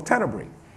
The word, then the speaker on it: Tenebrae: We do a service called Tenebrae. Bray. Okay. No, that means my dictionary pronounced it wrong. I thought it was Bray too. So Tenebrae.